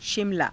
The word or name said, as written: shimalaa